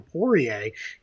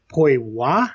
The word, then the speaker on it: Poirier: I've been calling him Poirier because I'm an American. But it's Vincent Poirier, I think is how you're supposed to say that.